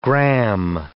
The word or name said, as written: gram